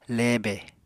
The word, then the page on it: lebe: apatani